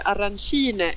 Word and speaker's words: arancine: de uttalas arancine i Palermo, medan i Catania så säger de arancini.